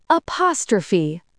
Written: apostrophe

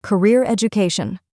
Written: career education

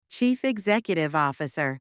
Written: chief executive officer

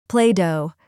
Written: play dough

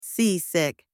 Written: seasick

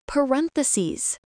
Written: parentheses

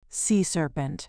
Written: sea serpent

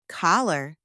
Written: collar

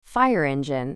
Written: fire engine

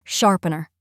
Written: sharpener

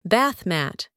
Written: bath mat

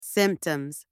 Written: symptoms

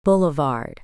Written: boulevard

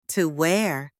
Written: to wear

